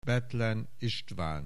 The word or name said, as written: Bethlen, István